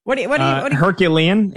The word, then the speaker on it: Herculean: So, like, Fernando Mendoza would have to do, like, Hercules, Heroclean. How do you say that word? – Herculean. It would take a Herculean season.